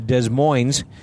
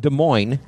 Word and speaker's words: Des Moines: We are driving right now from Des Moines, although I'm told it's pronounced Des Moines. – Oh, well, that's the way the French intended it. – I see. Okay. So in America, we say Des Moines. – But, you know, here's the thing. – In France, it's Des Moines.